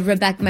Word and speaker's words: Rebecca: Yeah, so for me, Baraja Bendita, doble B, is actually, my name is Rebecca, my birth name is Rebecca, spelled with two B's.